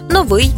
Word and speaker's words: новий: До речі, у більшості двоскладових прикметників наголос падає на закінчення. Новий, старий, гіркий, тонкий, товстий, тісний, вузький тощо.